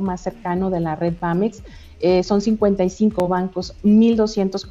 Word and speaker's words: BAMX: Nuestra página, bueno, sé que ya está apareciendo es BAMX, se pronuncia BAMEX pero no lleva la E,